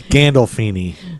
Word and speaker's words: Gandolfini: It's James Gandolfini. James Gandolfini. Oh. James Gross. Gandolfini. James Gandolfini's mother was in labor, and she was 10 centimeters dilated, and she was squeezing Gandolfini's head.